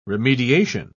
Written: remediation

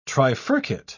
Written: trifurcate

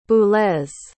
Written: Boulez

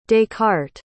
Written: descartes

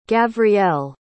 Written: gavriel

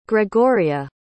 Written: gregoria

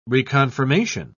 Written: reconfirmation

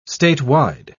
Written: statewide

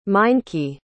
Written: meinke

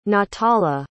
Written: natala

